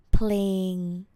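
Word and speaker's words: เพลง: The word song in Thai is playing with the spelling sala'e, pa'pan, loling, ngongu, playing. It's a cluster, so you pronounce pa'le together, playing, naka. Playing means songs. So learn Thai from a song would be เรียนภาษาไทยจาก playing เรียนภาษาไทย learn Thai จาก playing from a song.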